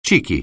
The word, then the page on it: cheeky: anglais